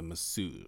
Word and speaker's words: masseuse: And he is a masseuse. Is that what you call it? Is that how you say it? Masseuse? – Yeah. Yep.